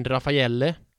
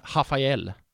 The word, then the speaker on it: Rafaelle: Hon mittbacken Rafaelle, som det stavas. Det uttalas ju Rafael.